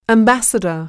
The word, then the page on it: ambassador: æmbǽsədər